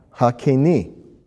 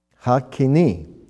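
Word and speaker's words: Hakeni (הקיני): It's He, Kuf, Yud, Nun, Yud. Reading from right to left, once again, He, Kuf, Yud, Nun, Yud. Pronounced Hakeni. Hakeni.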